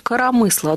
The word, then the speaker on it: коромысло: А вот пишется оно с двумя буквами «о» в первых двух слогах, ударение на третьем. Коромысло.